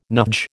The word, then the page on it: NUJ: American English